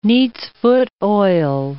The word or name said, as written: neat's-foot oil